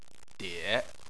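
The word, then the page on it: die: tone 3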